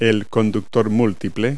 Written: bundle

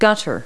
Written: gutter

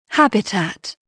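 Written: habitat

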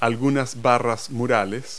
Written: wall bars

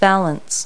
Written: valance

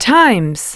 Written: times

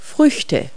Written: fruit

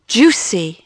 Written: juicy

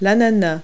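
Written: pineapple

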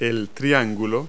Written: triangle

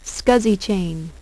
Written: SCSI chain

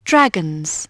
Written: dragons

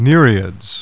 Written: Nereids

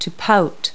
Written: pout

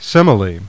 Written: Semele

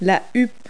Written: plume

